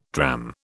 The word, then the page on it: dram: British English